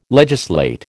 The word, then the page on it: legislate: American English